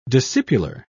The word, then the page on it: discipular: British English